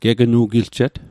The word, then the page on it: gegnu'giljet: Mi'gmaq